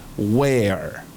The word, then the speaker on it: where: Y se escribe W-H-E-R-E. Where. Where. Pero tenemos que pronunciarlo con la boca un poco más cerrada y decir where. Y esa R del final, con R-R-E, es una R que es el comienzo de la R española, pero sin hacerla. ¿Ok?